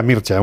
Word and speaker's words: Mircea: Rumana, rumana, él se llama Mircea. Sí, se pronuncia Mircea, uno que es muy friki de todos los rumanos, ya lo sabéis.